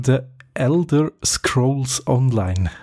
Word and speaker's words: The Elder Scrolls Online: Dann gibt es keine Grafik-Updates für The Elder Scrolls Online.